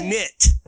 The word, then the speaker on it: shiznit: Shiznit. Knit. – Shiznit? – Like N-I-T. Shiznit. Well, the word is shit, Adam. – I mean, shiznick. – It's shiznit.